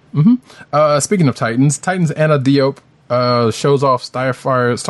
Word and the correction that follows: Diop: I think it's Jop. – Diop. – No, Jop. – Yeah. Jop. Okay. – Like pronounce it like J-O-P.